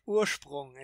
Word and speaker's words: Ursprung: Well, I mean, you're very close. Urs- Ursprung is the is the German pronunciation, and it, it means okay, origin, awesome.